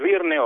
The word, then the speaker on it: Byrne: Esta vez el protagonista es un gran actor, Gabriel Byrne, o Gabriel Byrne, como se quiera pronunciar.